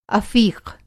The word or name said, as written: अफ़ीक़